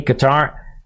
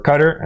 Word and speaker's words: Qatar: Because he has ordered the Mossad and other intelligence agency to hunt down and kill Hamas leaders in Lebanon, Turkey, Qatar, or Qatar, as some people pronounce it, in the coming years once the current war in Gaza tapers off.